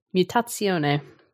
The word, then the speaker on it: Mutazione: Oh, it's Italian, Mutazione. – Mutazione. – Mutazione is a game that was released in 2019.